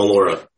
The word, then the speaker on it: Mollura: Mollura.